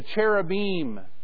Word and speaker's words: cherubim: All right, the seraphim that surround the throne, the angels, the cherubim, which is the proper way of pronouncing it, not the seraphim, it's the seraphim.